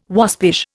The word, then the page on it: waspish: Indian